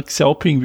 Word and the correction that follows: Xiaopeng: wie man spricht. Äh, Xiaopeng.